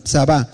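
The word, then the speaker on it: tsabá: Y viene de Tzabá, que significa T-S-A, B chica y A, un acento en la última, Tzabá.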